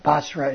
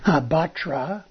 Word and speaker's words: Batra: Basra is a Ashkenazi pronunciation. Batra, with a T, is the Sephardic or the modern Hebrew pronunciation.